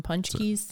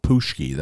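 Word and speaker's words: pączki: Some punch keys. – Some punch keys. Punch keys. I don't know, however you say it. – That's how you say it. But that's not how it's spelled.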